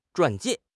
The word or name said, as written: 传记